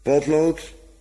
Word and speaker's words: potlood: We leggen dan heel nadrukkelijk de klemtoon anders. Een potlood.